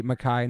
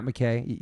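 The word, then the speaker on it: MacKaye: Well, I guess we can end it on that Ian Mackay is awesome, and you're the first person ever on this podcast to say Mackay, not McKay, because you know how to say it.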